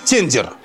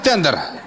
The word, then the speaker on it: тендер: Нашу страну погубит тендер. Ну, правильно говорить тендер. Но я просто вас вижу, почему хочу неправильно говорить.